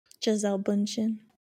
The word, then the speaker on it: Gisele Bündchen: Gisele Bündchen.